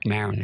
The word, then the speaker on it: Maron: Mark Maron is actually how he pronounced his last name.